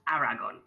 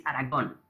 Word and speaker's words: Aragón: The name Aragón actually, it's actually not pronounced Aragón. It's, you know, English people pronounce it that way, so I'm used to that kind of pronunciation, but actually it's Aragón. Aragón, right? – Aragón. – Aragón. It's like a place in Spain.